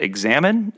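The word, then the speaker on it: Examen: You picked up this really cool prayer practice, I believe, and I'm probably going to mispronounce this, but Examine, is that right? – Yep, no, that's right.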